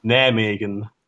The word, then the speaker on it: Nijmegen: Nijmegen.